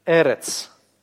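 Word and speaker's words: Эрец (ארץ): Видите ударение? «Эрец», «эрец». Современное название Израиля – это «эрец Исраэль».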